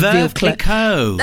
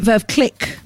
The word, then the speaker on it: Veuve Clicquot: Exactly what I said, Veuve Clicquot. Is that how you say it? – Yeah, Veuve Clicquot. Yeah. – Are you sure? – Yeah. – I've been calling it Veuve Click all my life.